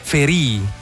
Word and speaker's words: Ferì: Parliamo di Ferì. Come si legge esattamente la cifra? – Ferì. Si ferì.